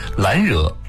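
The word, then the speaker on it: 兰若: 第五六句，懒惹静复静，茅茨深又深。先来解释两个词，懒惹，兰花的兰，惹字呢是若隐若现的若，这里是梵语的读音，广义上指的是禅修的寂静之处，这里代指树林。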